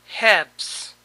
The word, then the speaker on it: halves: Halves. Aquí tampoco se pronuncia la L.